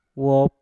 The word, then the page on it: wop: mpur